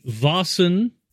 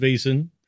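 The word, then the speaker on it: Vaesen: So, this is London Calling on the Happy Jacks RPG Network, in the Vasen, I'm trying to get the pronunciation right, because I for a long time said Vasen, and that's not right, and I have now corrected myself.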